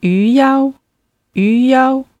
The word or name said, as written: yuyao